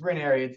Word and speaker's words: Ranieri: It's Ranieri.